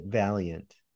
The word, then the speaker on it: Vaillant: He pronounces it Valiant.